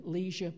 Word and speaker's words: Lesueur: If we have something green to ward off scurvy, please, canned Lesueur peas. One friend pronounces them leisure peas.